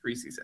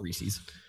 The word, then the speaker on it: Reese's: Yes, Reese's eggs. – Okay. It's not Reese's. It has to rhyme with pieces.